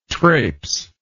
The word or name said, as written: trapes